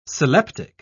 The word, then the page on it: sylleptic: British English